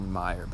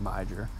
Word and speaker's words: Meijer: Well, it's really called Meijer, but I like to call it Meijer because it's spelled M-E-I-J-E-R.